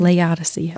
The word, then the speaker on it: Laodicea: It says, and to the angel of the church in Laodicea, did I say that right? – It's Laodicea. – Okay, Laodicea. At least that's what I know.